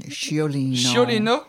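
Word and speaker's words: Sciolino: Ciolono, est-ce que je prononce mal Mm, Chiolino, C'est un oh nom italien. C'est un nom italien.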